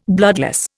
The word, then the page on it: bloodless: Indian